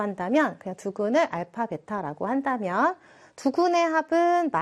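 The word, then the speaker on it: 알파 (α): 알파, 베타라고 읽어주시면 되고, 우리 방정식에서의 근을 표현하는 그리스 소문자다라고 그냥 생각하시면 됩니다.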